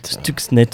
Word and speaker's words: Stuxnet: Stuxnet, voilà, tu, Beloulou le, prononce beaucoup mieux.